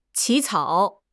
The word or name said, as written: 起草